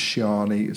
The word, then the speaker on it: Siani: but it's Shiani. So if I've pronounced that wrong, I am sorry, but S-I-A-N-I.